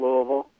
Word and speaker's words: Louisville: First of all, it's the correct way is Louisville, right? Not Louisville, to say that? – Yeah, it's we call it Louisville. It's for, you know, that's really the only way I know how to say it is Louisville, but yeah, you're close.